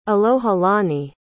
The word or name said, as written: alohilani